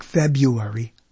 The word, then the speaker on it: February: Again, February, not New York, February. Teachers, people paid to teach people, and they say February, and then they spell it with the R. The R is silent. The R is not.